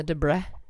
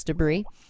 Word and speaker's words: debris: There was a collision, and then from the collision, the debris, with a silent S, debris.